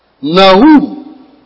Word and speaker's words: Nahum: Nahum.